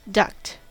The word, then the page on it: duct: American English